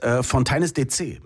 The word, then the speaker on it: Fontaines DC: Und du hast es wirklich falsch ausgesprochen, weil es ist Fontaines DC.